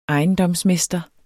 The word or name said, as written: ejendomsmester